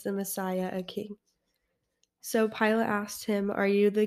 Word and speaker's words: Pilate: Pilate. – Pilate, the Roman governor.